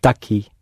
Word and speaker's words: daqui: It's all one word. D-A-Q-U-I. – Daqui. – Daqui. Stress on the E.